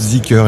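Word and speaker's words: Zeekr: Mais Z2EKR, qui est une marque qui va arriver aussi, vous la prononcez comment ? – Zicker ? – Alors ça va être Zicker, effectivement.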